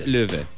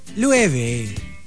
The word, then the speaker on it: Loewe: Ano, paano ulit yung totoong spelling ng L-O-E-W-E. – Yes. – Yes. – You think it's low, right? – No, but it's Louave. – Louave. Officially. – Louave. – Louave. – Because in German, lion is Louave.